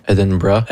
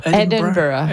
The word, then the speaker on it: Edinburgh: comment on le dit en anglais? – Edinburgh. – Edinburgh.